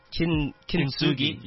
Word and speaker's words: Kintsugi: How do I say it? Is it Kintsugi? – Yeah.